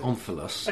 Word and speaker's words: omphalos: I pronounce it omphalos.